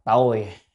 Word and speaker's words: tồi: Loại thứ hai, nhị ấm ma, vị ngũ ấm thân, cộng tương, chữ này đọc là tồi,